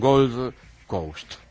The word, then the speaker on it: Gold Coast: En Australia, en Gold Coast. No sé si lo he pronunciado. – Muy bien.